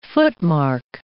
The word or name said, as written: footmark